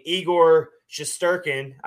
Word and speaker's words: Igor Shesterkin: So Igor Shesterkin.